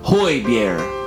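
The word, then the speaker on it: Højbjerg: For pronunciation, the J's are pronounced like Y's. So you have, if you look at it in two parts, you have hoy up front. But the Danes will always get you. That's what you've always said, Scott. I'm starting to believe you. You don't pronounce the G at the end, so you get Bier. Hoy Bier.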